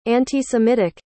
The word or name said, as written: anti-semitic